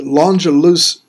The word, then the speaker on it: L'Angelus: She said the L'Angelus group, that's how you pronounce it, spelled like Angelus with an L in front of it, L'Angelus group, caught my attention because they, as a Catholic family, have explored deeply their Cajun roots through their music.